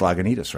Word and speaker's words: Lagunitas: one of them is you just pronounced Lagunitas right. Really, think about that for a moment, you know, because when we first began, you know, it's like I lived in the town of Lagunitas, and I knew that most people in the towns around us didn't pronounce Lagunitas. It was Lagunitas and Lagunitas and all sorts of.